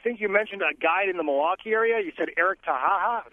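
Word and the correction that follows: Haataja: I think it's pronounced Hadia. – Hadia, it is. It's H-A-A-T-A-J-A. – Yeah.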